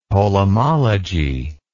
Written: polemology

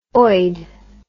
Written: oid

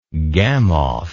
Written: gamow

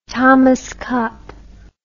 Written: thomas cup

